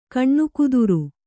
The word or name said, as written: ಕನಕೋದರ